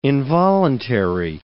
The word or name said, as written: involuntary